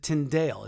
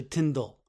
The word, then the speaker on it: Tyndale: And by the way, Tyndale is the proper pronunciation. Most of us have, including myself in the past, used it, pronounced it Tyndale. It's actually Tyndale.